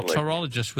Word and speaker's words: pterologist: A pterologist with a silent P.